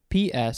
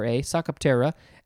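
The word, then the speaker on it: Psocoptera: The order name is Psoctoptera, which is spelled with a P, Psoctoptera, Psoctoptera, and the genus Lipposcalis.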